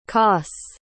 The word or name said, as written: koss